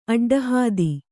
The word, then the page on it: ಅಡ್ಡಹಾದಿ: aḍḍahādi